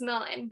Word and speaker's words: Milan: Um, like, for example, like my name is Milan, but a lot of people say Milan or Milan, mm-hmm.